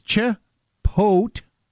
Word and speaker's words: Chipotle: So it's Chipotle. That's the emphasis is on pote. – Chipotle. – Chipotle.